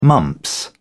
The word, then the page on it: mumps: British English